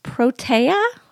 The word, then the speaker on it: Protea: So this is the, I think it's pronounced Protea. It's P-R-O-T-E-A. Protea? – I believe it.